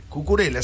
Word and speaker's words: Cucurella: Cucurella, sí, lo dije bien.